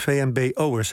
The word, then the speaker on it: VMBO'ers: De onderzoekers wijzen erop dat op het VWO stress door huiswerk een groter probleem is dan voor VBMO'ers. Zeg ik dat goed? VMBO'ers, hè?